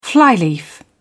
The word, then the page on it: flyleaf: British English